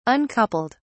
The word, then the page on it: uncoupled: British English